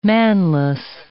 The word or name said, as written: manless